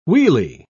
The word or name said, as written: wheelie